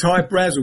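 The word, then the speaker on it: Hy-Brasil: There's Hyatt Brazel, High Brazil, people pronounce it High Brazil, and it's Brazil, okay. – yeah. But it's High Brazil because that's how the Irish would pronounce it, because it's an okay.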